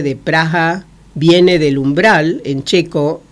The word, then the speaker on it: Praha: En checo se escribe P-R-A-H-A y la H suena como una J aspirada, Praha.